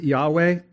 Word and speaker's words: Yahweh: We often pronounce it Yahweh.